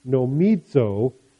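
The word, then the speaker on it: nomizo (νομίζω): And in Luke 3:23, the Greek word that describes Christ's relationship to Joseph it's pronounced nomizo, which means to hold by custom or by law.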